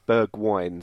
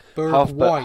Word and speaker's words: Bergwijn: Because I've watched a little video of him on Twitter, and it says, for our benefit, it's pronounced Bergwijn. So think Bergwijn. Half burger and then wine. Bergwijn.